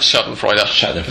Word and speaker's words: Schadenfreude: Yeah, they had to appeal to the Schadenfreude. Is that how you say it? – Schadenfreude. That's how we say it in English, anyway.